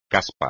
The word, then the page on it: caspa: espagnol